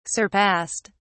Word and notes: surpassed: British English

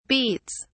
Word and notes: beats: British English

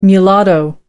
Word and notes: mulatto: American English

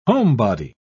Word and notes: homebody: British English

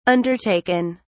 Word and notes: undertaken: British English

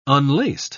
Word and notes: unlaced: British English

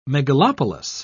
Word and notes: megalopolis: British English

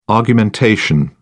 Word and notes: argumentation: British English

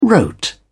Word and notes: wrote: British English

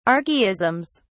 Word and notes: archaisms: British English

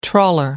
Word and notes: trawler: American English

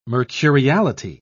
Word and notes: mercuriality: British English

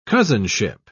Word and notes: cousinship: British English

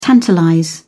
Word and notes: tantalize: British English

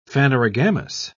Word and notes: phanerogamous: British English

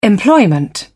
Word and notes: employment: British English

